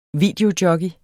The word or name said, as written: VIDEOJOCKEY